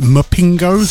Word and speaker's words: Mpingo: Never quite know how you pronounce that. Mpingo. It's Pingo with an M in front of it.